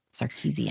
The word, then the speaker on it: Sarkisian: I was just about to ask, can you correctly pronounce the new coach's name? – Can't spell it, but that's how I pronounce it. Sarkisian.